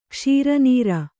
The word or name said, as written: ಕ್ಷೀರನೀರ